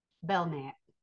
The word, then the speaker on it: Belknap: Or you could say, I'll just say there's a street called Belknap. And it's Belknap. P. Now you could say Belknap, and that's not how you say it, or Belknap. The K is silent.